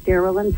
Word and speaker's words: Darillyn: Definitely, if a person was to put my name, Darilyn Patterson, I better spell it right, into a Google search, will say D-A-R-I-L-L-Y-N as in Nancy, Patterson with two T's, P-A-T-T-E-R-S-O-N.